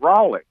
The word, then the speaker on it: Raleigh: He said it right, Raleigh.